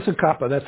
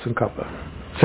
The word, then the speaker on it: Tsongkhapa: Lama Tsongkhapa. That's how you pronounce it. T is silent. Tsongkhapa.